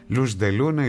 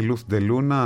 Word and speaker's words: Luz de Luna: Λους Ντελούνα ή Λουθ Ντελούνα, ανάλογα με την προφορά, αν το λέει κάποιος από την Κωσταρίκα, Ισπανός, Ισπανίδα, Αργεντίνος κτλ. Pues desde que te fuiste, yo no he tenido Luz de Luna.